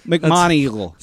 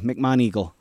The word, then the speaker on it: McMoneagle: Joe McGonigal, I think I'm saying that right. McMoneagle. Sorry. He's a U.S. Army Chief Warrant Officer. – McMoneagle? – It somehow got worse. – McMoneagle. McMoneagle.